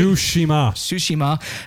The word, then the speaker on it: Tsushima: Playing another video game, Ghosts of Tsushima. Is that pronounced right? – Tsushima.